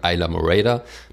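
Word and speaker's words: Islamorada: ich war in Isla Morada oder wie die Einheimischen sagen, Isla Morada. Das mussten wir auch erst lernen, dass es nicht Isla Morada heißt, sondern Isla Morada spricht man das aus.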